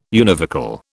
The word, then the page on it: univocal: British English